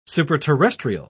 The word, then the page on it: superterrestrial: British English